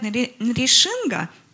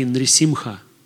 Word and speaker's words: Нрисимха: Everywhere they say Нришинга. Нришинга. And here we say Nrisimha. – Again, who devotees in Russia pronounce not correctly Nrisimha. – Nrisimha. – But everywhere in other places, Nrisinga. – Other places are wrong. – В других местах неправильно. – Russia, they're right.